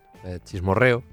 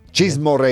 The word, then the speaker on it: chismorreo: How do you say it? Chismecho. Come on. Save me. – Chismes, this is good. Chismorreo. – Chismorreo, yeah. That's the one.